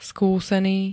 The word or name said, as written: skúsený